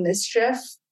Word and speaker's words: MSCHF: They're called Mischief. Their name is spelled M-S-C-H-F.